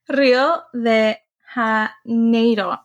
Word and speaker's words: Rio de Janeiro: How do you say that in Spanish? Spanish. – I thought it was, Rio de Janeiro. Because it's E-I, not I-E. – So I was going to say it like in English, and then my brain was like, wait, Spanish. Rio de Janeiro, I think. Did I say it right? – Yeah.